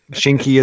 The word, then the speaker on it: Shinki: Shinki. – Shinki. – Kinky. We'll have to remember that. Shinky is, it rhymes with kinky.